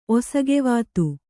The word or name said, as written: ಒಸಗೆವಾತು